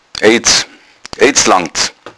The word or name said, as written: aiddz